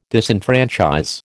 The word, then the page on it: disenfranchise: American English